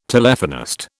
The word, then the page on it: Telephonist: British English